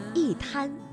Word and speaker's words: 一摊: 因为一摊当中的摊是阴平字，也就是一声字，所以呢，我们应该读去声，一摊。